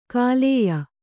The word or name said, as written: ಖಲ್ಯೆ